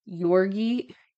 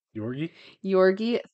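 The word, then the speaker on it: György: So, the investigation was handled by the king's highest ranking representative, Jorgi, I can't say it, the G is silent. – Jorgi Thurzo.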